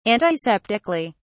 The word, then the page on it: antiseptically: British English